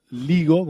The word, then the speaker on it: LIGO: bueno, el, LINGO, o LIGO, como se escribe, es el la, eh, Laser Interferometer Gravitational Wave Observatory, o dicho en español, el Observatorio de Interferometría Láser de Ondas Gravitacionales.